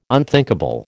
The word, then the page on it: unthinkable: American English